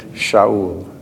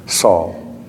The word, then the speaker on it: Saul: And they laid aside their robes at the feet of a young man named Shaul, as Alfred likes to pronounce it, Saul.